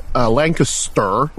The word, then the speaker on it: Lancaster: This friend who emailed me, he lives in Lancaster, which is how they pronounce it, in Pennsylvania.